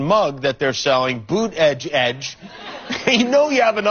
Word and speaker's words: Buttigieg: Buttigieg is a Maltese name. It means owner of chickens. – All right, so you pronounce it Boot, B-O-O-T, Edge Edge.